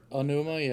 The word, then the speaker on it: Onuma: Onuma.